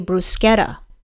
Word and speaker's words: bruschetta: Pork and bruschetta, although as we learned in pronunciations, it's actually bruschetta.